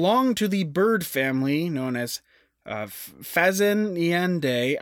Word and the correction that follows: Phasianidae: I butchered it. It's like Phasianidae.